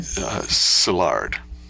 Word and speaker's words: Szilard: Yes, I mean, and then you quote Leo Szilard. Is that the way to pronounce his name? – Szilard, Hungarian, so...